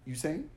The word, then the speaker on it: Usain: Usain? – Yep. – What did I say the first time? – You said that. I just want to make you say it again. – Is that not right? Usain Bolt? – You're right.